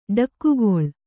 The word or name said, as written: ಠಕ್ಕುಗೊಳ್